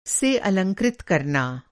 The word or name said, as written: अलंकृत